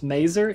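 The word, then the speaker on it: Masur: So Clark is played by Richard, I think it's pronounced Maser, M-A-S-E-R.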